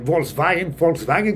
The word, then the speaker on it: Volkswagen: Como Volkswagen, que se pronuncia como si lo dijeras con una F, tiene un acento ahí medio curioso.